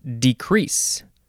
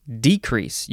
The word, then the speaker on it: decrease: The first word in this episode is decrease or decrease. You can emphasize the first syllable or the second syllable.